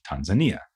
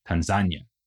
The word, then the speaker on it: Tanzania: Nyerere and a Zanzibari leader avoided this possibility by negotiating a federation between Zanzibar and Tanganyika, giving us the new country of Tanzania, or as it is also sometimes pronounced, Tanzania.